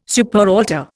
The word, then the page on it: superaltar: Indian